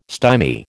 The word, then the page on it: stymie: American English